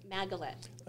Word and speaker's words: Maggelet: Maggelet.